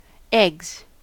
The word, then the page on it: eggs: American English